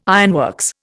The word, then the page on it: ironworks: Indian